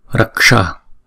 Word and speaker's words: raksha: Chữ này chúng ta đọc là raksha raksha, thấy không? Đọc thật là chậm nè, ra k sha, thấy không? À, chúng ta đọc ghép lại, đọc thật nhanh là raksha raksha, không?